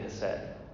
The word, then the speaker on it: hesed: It's spelled H-E-S-E-D. In the Hebrew, it's a little bit different, but that's how we pronounce it in English.